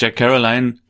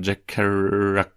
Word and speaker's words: Jack Kerouac: Okay, also nicht Jack Caroline, dann hat der Schauspieler ein bisschen genuschelt hier, sondern Jack Kerouac.